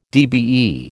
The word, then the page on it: DBE: American English